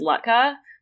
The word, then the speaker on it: latke: There's like actually so a lot of like our A's and O's become U sounds, and it actually one that comes up a lot is Lutka, which is spelled like L-A-T-K-E.